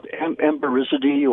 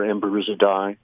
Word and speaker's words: Emberizidae: Yeah, Emberizidae or Emberizidae. – Depending on how you choose to resolve the Latin diphthong.